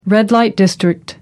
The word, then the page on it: red light district: American English